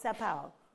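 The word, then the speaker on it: São Paulo: How do you pronounce it? Sao Paulo.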